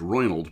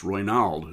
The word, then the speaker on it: Ronald: I always thought it was Roynald, but it's Roynald. I heard Nat King Cole on a broadcast introduce him, so I will go with Nat King Cole's pronunciation.